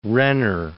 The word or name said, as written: Renner